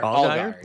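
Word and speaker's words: Allgeier: I forget how you pronounce his last name. Allgaier. – I think it's Allgaier. – Allgaier. Yeah. Okay, so they have him.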